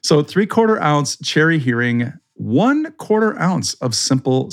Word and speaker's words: Heering: Then you're going to take your cherry Heering. And by the way, great job on saying it correctly. It's not herring, it's hearing.